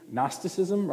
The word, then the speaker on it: Gnosticism: It begins with the G, G-N, silent G, Gnosticism, right?